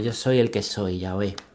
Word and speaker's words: Yahvé: Y el, pues eso con, con el sonido vocal, como lo pronuncian los judíos, se pronuncia Yahvé, ¿vale?